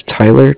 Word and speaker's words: Tyler: My name is Tyler, T-Y-L-E-R. – Okay, and the name of the company? – Cardiff. It's spelled C-A-R-D-I-F-F, as in Frank Frank.